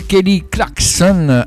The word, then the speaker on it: Kelly Clarkson: Elle s'appelle Kelly Clarkson, avec le K qu'on prononce bien, s'il vous plaît.